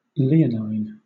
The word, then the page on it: leonine: British English